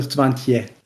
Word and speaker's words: Swantje: Mit T-J-E. Oder Swantje mit T-C-H. – Wer schreibt das denn mit C-H? – Ja, sprechen, nicht schreiben. Swantje oder Swantje? – Swantje. – Swantje. Also T-J-E. – Ja, Swantje. – T-J-E, ja.